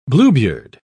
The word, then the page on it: bluebeard: British English